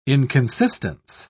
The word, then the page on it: inconsistence: British English